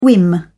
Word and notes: whim: British English

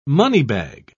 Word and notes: moneybag: British English

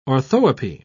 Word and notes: orthoepy: British English

